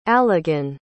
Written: alagan

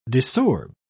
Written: desorb

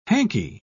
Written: hankie

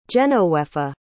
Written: genowefa

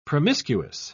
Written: promiscuous

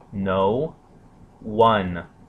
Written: noone

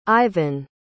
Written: Iven

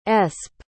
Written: espe

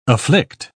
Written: afflict

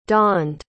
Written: dawned

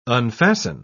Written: unfasten

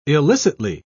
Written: illicitly

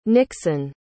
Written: Nickson

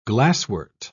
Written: glasswort

